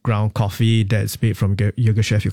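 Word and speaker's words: Yirgacheffe: But is that how you could pronounce it? Say that again. – Yeah, Ethiopia Yogeshef. – Yogeshef. – That's right.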